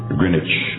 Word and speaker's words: Greenwich: Our prime meridian in the world is located in Greenwich. That's how they pronounce it. It's spelled Greenwich, right out of London.